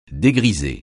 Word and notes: dégriser: français

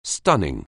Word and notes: stun: anglais

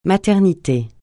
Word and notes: maternité: francais

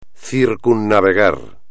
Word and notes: circunnavegar: espagnol

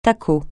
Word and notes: tacot: francais

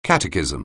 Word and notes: catechism: anglais

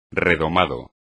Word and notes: redomado: espagnol

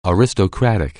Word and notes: aristocrat: anglais

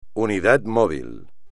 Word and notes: unidad móvil: espagnol

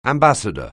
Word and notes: ambassador: anglais

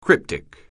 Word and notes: cryptic: anglais

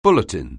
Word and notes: bulletin: anglais

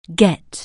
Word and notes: get: anglais

